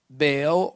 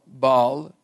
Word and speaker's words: Baal: And David, underline that, Baal or Baal, people pronounce it, Perazim.